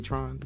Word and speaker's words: Trone: Freddie Trone. Or Tron. Did I say that right? Freddie Tron? Just T-R-O-N-E. – Tron. – Tron. Okay. We're going to call him Tron.